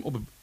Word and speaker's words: Op het Broek: Tim Optbroek. Hoe spreek jij mijn naam eigenlijk uit, Joy? – Tim Op het Broek. – Zie je, weer verkeerd.